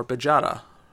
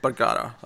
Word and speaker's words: Bergara: Steve Orlando writing art by Matias Bergara or Bajara. That's a fun name. I'll go with Bergara. I feel like uh, it sounds more fun to say with a hard G.